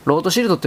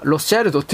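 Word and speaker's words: Rothschild: 1820 年以降においては、いわゆるあの、ロート・シルト・家、ロートシールドファミリーの時代だったということ。ロートシールドってわかりますか英語読みではロスチャイルドって読みます。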